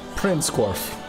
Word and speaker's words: Prinskorv: Prinskorv. – Prinskorv.